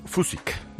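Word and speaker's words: Fučík: Oye, ¿cómo has pronunciado el checo? – ¿Fusik? Julius Fusik. – ¿Fusik? – Claro.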